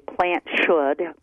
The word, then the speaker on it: PlantSkydd: Actually, if you were Swedish, you'd say Plant Skid.